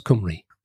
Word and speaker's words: Cymru: But yeah, I'm sort of remembering it because C is pronounced K in Welsh. – How can they tell? – If you look at Cymru, which is Welsh in Welsh, or Wales in Welsh. So it's spelt with a C, but it's pronounced Cymru.